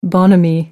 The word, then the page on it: bonhomie: American English